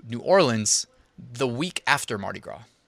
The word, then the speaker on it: Mardi Gras: I don't think you pronounce the S. Mardi Gras.